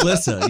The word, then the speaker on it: Lyssa: L-Y-S-S-A, Lisa. – That's Lissa. Yeah, that's... – No, his Lissa. – Daughter. – I know, but I'm like... This, the yeah, there's phonics, dog. It's Lissa.